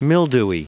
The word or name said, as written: mildewy